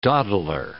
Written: dawdler

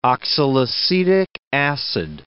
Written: oxalacetic acid

